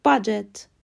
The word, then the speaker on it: budget: لغت آخر B U D G E T به معنی بودجه تلفظ درست باجت امیدوارم پادکست امروز براتون مفید بوده باشه.